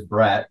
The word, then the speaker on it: Braet: So, even if no one can never pronounce it right, it is Braet, but everybody says Braet, Braet, everything.